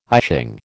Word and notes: I Ching: American English

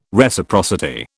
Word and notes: Reciprocity: British English

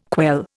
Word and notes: quell: Indian

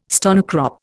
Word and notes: stonecrop: Indian

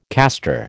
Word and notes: caster: American English

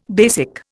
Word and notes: BASIC: Indian

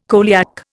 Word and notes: coeliac: Indian

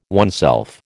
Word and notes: oneself: American English

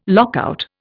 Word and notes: lockout: Indian

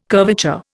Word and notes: curvature: Indian

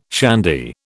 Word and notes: shandy: British English

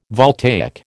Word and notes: voltaic: American English

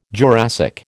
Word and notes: Jurassic: American English

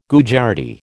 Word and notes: Gujarati: American English

